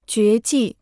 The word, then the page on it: 绝技: jué jì